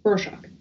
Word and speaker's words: Rorschach: There's a character in it whose name is Rorschach, and that's how that's pronounced, right? Like the inkblot test? – Rorschach.